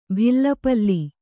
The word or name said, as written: ಬೆಲ್ಪಾಲೆ